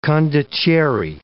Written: condottiere

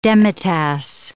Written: demitasse

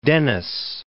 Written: Denys

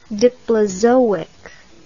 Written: diplozoic

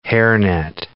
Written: hairnet

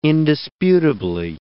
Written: indisputably

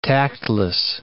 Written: tactless

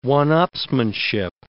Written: one-upsmanship

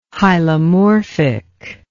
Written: hylomorphic

